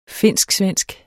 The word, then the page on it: finsk-svensk: [ ˈfenˀsgˈsvεnˀsg ]; [ ˈfenˀsgˌsvεnˀsg ]